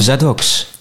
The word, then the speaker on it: Zadoks: Zadoks, welkom bij Loopraad Gideon.